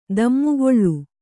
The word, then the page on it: ದಮ್ಮುಗೊಳ್ಳು: Kannada